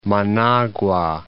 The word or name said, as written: Managua